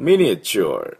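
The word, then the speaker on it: miniature: で、ミニチュアルというふうに発音します。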